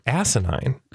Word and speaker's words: asinine: And somebody tweeted something that Scott Baio had said and made a point that it was like asinine. And I thought, oh, ooh, this'll be good. – Emphasis on first syllable. Asinine.